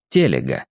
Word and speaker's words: Телега: Студенты и знакомые за глаза называли иногда Егора Николаевича Телегой, но сам профессор утверждал, что фамилия его произносится с ударением на первом слоге — Телега, и сдержанно обижался, если ударение путали.